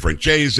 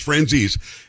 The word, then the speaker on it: Franzese: His last name is Franzese. You've heard people say Francese, Franzijap. I mean, they say all sorts of things, just like with my last name, but it's Franzese is how we're pronouncing it.